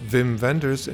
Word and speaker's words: Wim Wenders: Hi, this is Wim Wenders, correctly pronounced Wim Wenders, and you're listening to the Podhouse Film to the Talkhouse Film Podcast, not the Podhouse Film Talk.